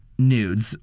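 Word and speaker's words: NVDES: The song I selected for this category is The Goddess Way by Nudes, which is how it's pronounced, but it's N-V-D-E-S.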